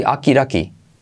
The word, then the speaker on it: okidoki: Yo lo pronuncio así, akiraki, akiraki, porque las D se convierten en R suave.